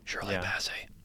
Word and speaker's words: Shirley Bassey: Shelley Bassey? Bassey? I don't know how to say her last name. – Shirley Bassey. – Shirley Bassey.